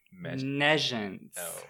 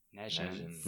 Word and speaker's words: nescience: Negence. – Lack of knowledge or ignorance. It's spelled N-E-S-C-I-E-N-C-E. – Nescience. – Negence.